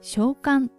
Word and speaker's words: 小寒: 字の通り小さく寒いと書いて小寒と読みます。